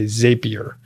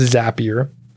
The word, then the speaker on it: Zapier: I used to say Zapier, but I think it's Zapier. So I used to say the wrong thing.